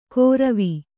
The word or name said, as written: ಗೊರವಿ